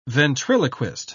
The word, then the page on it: ventriloquist: British English